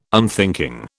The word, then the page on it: unthinking: British English